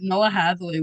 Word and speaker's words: Noah Hathaway: I also read, a little off topic from this, but I also read that Noah Hathaway was, I hope I'm getting his name right.